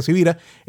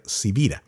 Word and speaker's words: Sivira: Se escribe con S de semana y con B de Victoria, Carlos Javier Sivira.